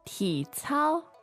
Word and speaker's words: 体操: 体操, 体操. – So is that a third and first, is it? – Correct. – Good, so third tone, first tone. One more time, please. – 体操.